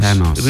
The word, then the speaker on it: Thanos: Now, I don't know how to pronounce his name. It's Thanos, or whoever, comes for the Infinity Stones. – Thanos.